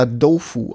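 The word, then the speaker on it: Dofu (豆腐): Und das andere Wort im Chinesischen das hätte ich vielleicht vorneweg sagen können, dann mache ich es jetzt an dieser Stelle, wird das Tofu weicher ausgesprochen, da wird es nämlich eher Dofu ausgesprochen.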